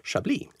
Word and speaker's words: Chablis: Dagens Visste du kommer handla om en vinregion i norra Frankrike som ibland får heta Chablis eller Chablis. Chablis, Chablis, beroende lite grann på uttalningsförmåga och härkomst. Men vi på Vinguiden är ju lite mer sansade än så. Så vi vet ju att dagens ämne är Chablis.